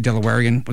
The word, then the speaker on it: Delawarean: Did I pronounce that correctly? Delawarean? Was that okay? Yes. Is that okay? Okay, good. Just making sure that was a good pronunciation.